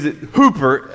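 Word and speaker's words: hooper (ὑπέρ): That word for, F-O-R, in the Greek is hooper. That's what it means. Hooper, that's how it's said in the Greek, hooper.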